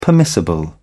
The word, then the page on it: permissible: British English